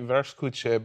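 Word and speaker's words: Wurstküche: I've had there's a place in LA called it's pronounced like Vers Kuche, but it's spelled Worst Cooch.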